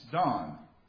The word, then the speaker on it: Dan: which looks like Dan, D-A-N, but is pronounced Don.